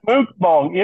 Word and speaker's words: mukbang: Is that B-A-N-G? Like a designer dog? – It's like a. we are so off the rails. It's M-U-K-B-A-N-G, pronounced mukbang.